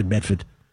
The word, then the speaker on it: Medford: There's also Medford, pronounced Medfa, he says. It's really Medford.